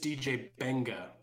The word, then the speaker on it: Mbenga: DJ Mbenga. I'm sorry, it's just DJ Benga. That is how you pronounce it, but it's spelled M-B-E-N-G-A.